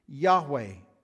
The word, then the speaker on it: Yahweh: In Hebrew, it looks like this, it's represented by these English letters, YHWH, and it's pronounced Yahweh.